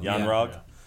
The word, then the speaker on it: Yon-Rogg: And she wakes up and decides to go have a fight with Jude Law, who is Yon-Rogg. Is that how you say his name? – I think it sounds Yon-Rogg. Yeah.